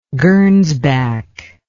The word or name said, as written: gernsback